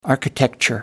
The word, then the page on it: architecture: American English